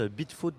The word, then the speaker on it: Beatfoot: du trio israélien nommé Beatfoot. Alors moi, c'est un nom que je trouve assez assez cocasse. Bitfoot donc il s'écrit B-E-A-T, foot comme un pied, qu'on pourrait littéralement traduire par pied de bite.